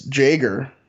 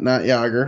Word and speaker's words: Jager: So it's Jager, not Jager, unfortunately. Disgusting. Gugielka. So Jager Gugielka. – You don't even pronounce the Y? – No.